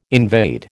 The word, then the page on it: invade: American English